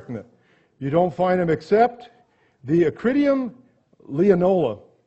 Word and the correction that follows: lineola: Nope, I said it wrong. Lineola, common in Baghdad, has a sting in its tail, according to Smith's Bible Dictionary.